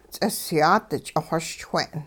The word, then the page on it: ts'isyaat ch'ixoschwe'n: Hupa